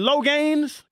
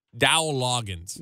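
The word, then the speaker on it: Dowell Loggains: What is the pronunciation? – Dowell Loggins.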